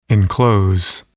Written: enclose

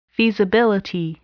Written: feasibility

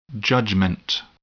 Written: judgment

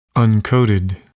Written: uncoated

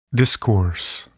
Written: discourse

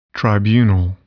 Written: tribunal

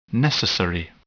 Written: necessary

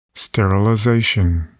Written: sterilization

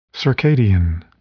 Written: circadian

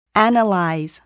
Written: analyze

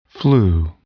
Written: flu

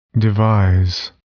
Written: devise

